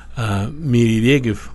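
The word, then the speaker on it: Мири Регев (מירי רגב): Я видел совершенно очаровательную фотографию члена Ликуда Мири Регев, по-моему, так произносится ее имя? – Да.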